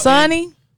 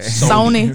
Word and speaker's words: Sony: Sonny. – Okay. – I be saying it wrong. – I mean, you country, so it's Sonny. – Right. – Sony.